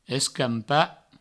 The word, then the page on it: escampa: escampo; provençal